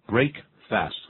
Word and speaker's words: breakfast: 那把 the 去掉，然后呢就变成 break fast 合在一起，念成 breakfast, 那个 fast 反而念成 first。